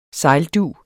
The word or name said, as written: sejldug